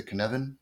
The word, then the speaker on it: Cynefin: I don't know if you're familiar with the Kinevin framework developed by Dave Snowden. – Okay. – It's spelled CYNEFIN, and it's what we call a sense making framework.